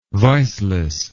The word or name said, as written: viceless